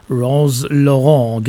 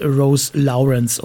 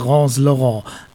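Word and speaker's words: Rose Laurens: Genau, so wird sie ausgesprochen. Viele meinten ja, weil es später auch eine englische Version von diesem Song gab, das ist Rose Lawrence oder wie auch immer. Nee, es ist Rose Laurent.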